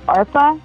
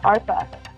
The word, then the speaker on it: ARPA: So that name that everyone calls me in the Bengali community is ARPA. It's spelled ARPA. So that's my other name. – ARPA, almost like ARP, but yeah. – ARPA. – Yeah, ARPA.